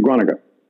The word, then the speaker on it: Groningen: And it's spelled Groningen. They pronounce it Groningen.